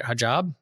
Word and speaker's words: hijab: Hijab. She wears like full body hijab.